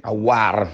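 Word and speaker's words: Aouar: Rimarchiamo anche che la Roma aveva delle assenze importanti, non c'era Di Bala, Pellegrini è subentrato dopo l'infortunio di Aurar eh, che comunque, Awar, meglio precisare la pronuncia,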